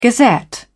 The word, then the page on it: gazette: British English